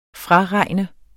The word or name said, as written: FRAREGNES